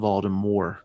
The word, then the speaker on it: Voldemort: I listened to an audiobook of it one time and they pronounced his name Voldemort. The T was silent. – Wow. Well, I mean, it's technically French, but they did it wrong. You pronounced the T. In French, you wouldn't, but yeah. Yeah. – Olyra Fersen. – Fersen. – F-A-E-R-S-O-N.